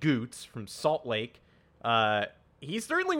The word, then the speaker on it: Gutz: We were hanging out with the beer's new mascot, Goots, whose name is spelled Guts.